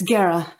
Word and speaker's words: Geàrra: And the red cap is like, fuck, pulls the lollipop out and goes, you're Gera? Is that how you say it? – Yeah. – Gera? – It's Gera. Yeah.